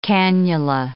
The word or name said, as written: cannula